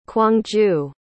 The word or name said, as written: kwangju